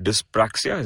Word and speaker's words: dyspraxia: Harsh, you were born with a condition called dyspraxia.